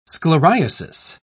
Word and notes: scleriasis: British English

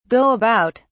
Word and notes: go about: British English

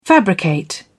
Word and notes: fabricate: British English

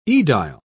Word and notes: edile: British English

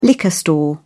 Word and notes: liquor store: British English

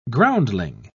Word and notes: groundling: British English

